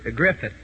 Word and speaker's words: Griffith: You know, we're awful glad to have Deacon Andy Griffin with us. Griffith. He said pronounce it with a T-H, so it's Deacon Andy Griffith.